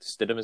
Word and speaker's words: Stidham: Stidham is how you say his last name? Yep, him.